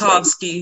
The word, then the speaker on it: Tchaikovsky: And I hope I'm pronouncing his last name right, because I'm not going to try the first Tchaikovsky. – One. – Tchaikovsky.